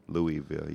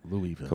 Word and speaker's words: Louisville: The bat, the bourbon, Louisville. You gotta leave the S. The S is silent. – Louisville.